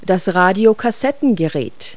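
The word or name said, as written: audio system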